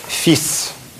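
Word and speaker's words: fils: Сын читается фис.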